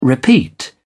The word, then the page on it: repeat: British English